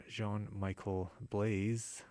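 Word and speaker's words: Jean-Michel Blais: After Tania Tagak, we're going to hear something from Jean-Michel Blaise, or I believe that's how you pronounce it, B-L-A-I-S,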